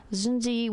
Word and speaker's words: Xunzi: This is in chapter 2 of the ancient Chinese philosopher Zunzi, which I have a really hard time pronouncing because it's spelled X-U-N-Z-I, Basic Writings, chapter 2.